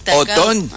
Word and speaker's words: Otón: Que fue San Otón. – Con acento en la O al final.